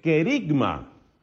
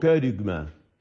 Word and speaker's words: kerygma: Esas señales muy claras se suelen llamar el querigma. Resulta que he aprendido que en griego propiamente la pronunciación es algo así como kerygma.